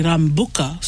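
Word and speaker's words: Rabuka: And lucky I already know the Prime Minister, Sitsiviri Rambuka. So, but the way it's spelled does not have the M.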